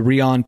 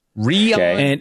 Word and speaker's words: Reon: And Sony has just come out with a new device called the Rion Pocket. – Rion Pocket? How do you spell that? – The Rihanna Pocket. – R-E-O-N.